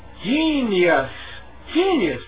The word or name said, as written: genius